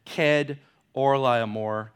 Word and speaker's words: Chedorlaomer: Ked, it's not Ched, Kedorlaomer, but I know it's Ked,